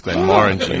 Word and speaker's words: Glenmorangie: Glenmorangie is the right way to say it. – Like Moran, like Dylan Moran, the comedian.